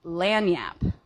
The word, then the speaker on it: lagniappe: Oh, so the word is lanyap. – I don't it is spelled L-A-G-N-I-A-P-P-E.